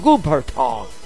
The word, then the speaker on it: Cooperstown: Cooperstown.